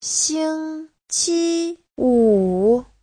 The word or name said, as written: xing1qi1wu3